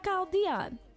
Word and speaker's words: Kyle Dion: I just found out about Kyle Dion. Did I say his name right? – Yeah, you did.